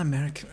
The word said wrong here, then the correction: Americium: Americium, Americium sounds good.